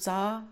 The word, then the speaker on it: czar: Okay, Russian apparatchik borscht, za, okay, it's pronounced za,